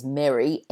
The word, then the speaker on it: Merry: And then, of course, there's a third word which some people get confused about, which is Merry, M-E-R-R-Y, as in Merry Christmas. That rhymes with ferry.